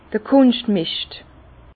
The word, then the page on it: d'r Kunschtmischt: Bas-Rhin; Herrlisheim